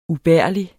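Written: UBÆRLIGE

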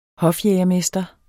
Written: hofjægermester

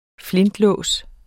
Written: flintelås

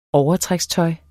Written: overtrækstøj

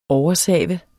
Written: oversave

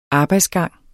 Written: arbejdsgang

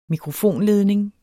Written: mikrofonledning

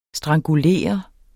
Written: strangulerer

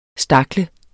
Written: stakle

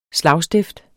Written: slagstift

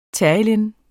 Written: terylene®

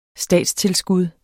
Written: statstilskud

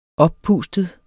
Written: oppustet

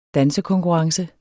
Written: dansekonkurrence

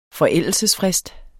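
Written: FORÆLDELSESFRIST

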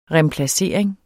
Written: remplacering

